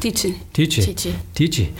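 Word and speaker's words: Tite: Wait, because Teach was getting his... Is it Teach? Is that how it's pronounced? – Teachy.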